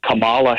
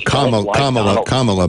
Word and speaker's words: Kamala: Do you really think that Kamala Harris, voting for Kamala Harris... – It's Kamala, by the way, but keep going.